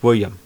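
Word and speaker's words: will.i.am: that's just William," and you say, "No, Jack, you have to pronounce it Will I Am."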